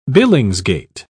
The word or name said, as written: billingsgate